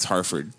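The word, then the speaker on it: Hartford: People that actually went there, you say it with an F. – Harford. – Yeah. A lot of people say it wrong. It's Harford.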